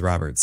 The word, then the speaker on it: Robert: It's the MLB pronunciation guy says Robert. So whatever.